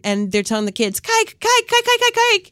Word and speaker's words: kijk: The Dutch word for look, to look, is kijk. I believe it's spelled K-I-J-K, kijk.